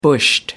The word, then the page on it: bushed: American English